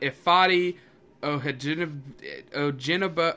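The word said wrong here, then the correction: Ifeadi Odenigbo: I can't pronounce his last name. – It's Ifedi Odenabo.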